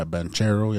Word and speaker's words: Banchero: That's what I was going to say was, like, I wanted to watch, how do you say his name? Paolo Banchero? – Yeah, Banchero, yeah.